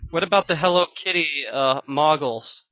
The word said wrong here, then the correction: Moogle: Okay, I know it's Moogle. It's Moogle like Google, right?